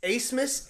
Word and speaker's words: Abmas: Well, I don't know anyone on Arkansas, but I definitely don't know of anyone on Arkansas who's got a silent B in their name that's actually an S. – Yeah. – Like Oral Roberts does. You have Max Asmus, A-B-M-A-S.